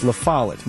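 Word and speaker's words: La Follette: Doug LaFossil. Sorry, I mispronounce that all the time. It's LaFollet.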